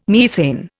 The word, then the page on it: methane: Indian